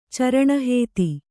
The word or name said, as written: ಚರಣಹೇತಿ